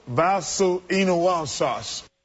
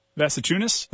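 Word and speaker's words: Valančiūnas: Now, are you sure that you got the pronunciation right on his name? – Valanciunas? – Yes. – I feel confident about it. I said it sounds good, doesn't it? – Roy, you want to throw in a vote?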